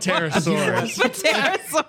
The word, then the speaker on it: Pterosaur: Pterosaur. – I'm going to say probably the P is silent. Just a shot in the dark. – I hope it's not. – Pterosaurus.